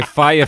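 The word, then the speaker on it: Fyre: Is it FYR? F-Y-R, R-E. Are we saying this? Is it correct? Are we pronouncing it right? – Oh, Firefest. No. Oh, right. So it turns out it was fire all along.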